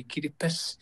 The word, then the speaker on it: Kiribati: Oh, it says we don't have the S in our language, so we say Kiribati.